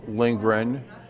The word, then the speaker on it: Lindgren: Rolf Lindgren, R-O-L-F-L-I-N-D-G-R-E-N, and I live in Middleton, Wisconsin, and I'm the Pints and Politics Director of the Republican Party of Dane County.